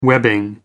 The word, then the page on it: webbing: American English